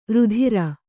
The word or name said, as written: ರಾತ್ರೆ